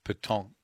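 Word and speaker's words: pétanque: And what's well, talk to us about the club, the Wollongong Petonk Club. You actually don't pronounce the K, do you? – The true no, French no. Petonk. Yeah, because it's Q U E, spelled with Q U E.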